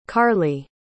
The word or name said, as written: karlie